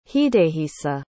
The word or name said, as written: hidehisa